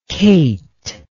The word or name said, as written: kate